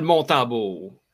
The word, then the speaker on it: Montembeau: Samuel Montembeau.